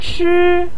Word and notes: chi1: pinyin

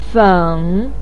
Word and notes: feng3: pinyin

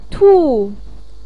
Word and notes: tu4: pinyin

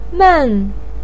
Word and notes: men4: pinyin

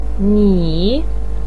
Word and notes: ni3: pinyin

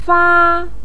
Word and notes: fa1: pinyin

